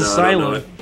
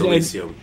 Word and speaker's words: Elysium: Oh, I thought it was Asylum. – I don't know that. – Is it Elysium or Asylum? – No, I think it's Elysium. – I didn't know how to pronounce it.